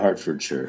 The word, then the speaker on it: Hertfordshire: Herefordshire uh, in Hertfordshire. Hertfordshire, is that how you say it? – Yeah, Hertfordshire.